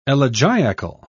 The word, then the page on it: elegiacal: British English